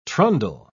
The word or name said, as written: trundle